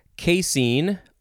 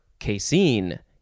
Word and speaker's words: casein: Next is casein or casein. You could emphasize either syllable. It is spelled C-A-S-E-I-N, casein.